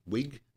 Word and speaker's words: Wiig: Kristen Wiig, is that how it's pronounced? Wiig? – Yeah.